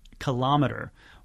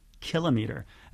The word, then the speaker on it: kilometer: They did a very they spent a big portion of their budget on a series of PSAs that used the pronunciation kilometer, which is based on barometer, thermometer, when it's supposed to actually be kilometer.